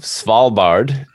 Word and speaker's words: Svalbard: of Svalbard, if I'm yes.